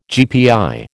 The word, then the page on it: GPI: American English